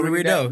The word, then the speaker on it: Rideau: Bino Rideau. I say it like the Bordeaux for the Jordan, so like Rideau or something.